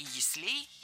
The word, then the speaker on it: яслей: Было сказано «система ясель», но это неверно. Правильный вариант – «яслей» с ударением на первом слоге и окончанием «ей». Что интересно, во многих словарях даже есть предупреждение. «Ясель» и «яслей» – неправильно, только «яслей».